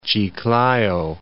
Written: Chiclayo

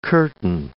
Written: curtain

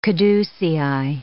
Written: caducei

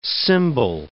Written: symbol

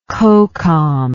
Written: cocom

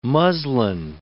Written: muslin

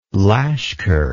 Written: lashkar

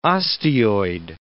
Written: osteoid

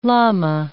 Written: lama

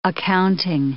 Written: accounting

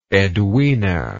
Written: edwina

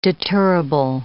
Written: deterrable

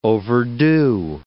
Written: overdue

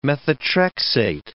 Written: methotrexate

